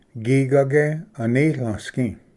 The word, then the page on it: ᎩᎦᎨ ᎠᏂᏢᏍᎩ: gigage-anitlvsgi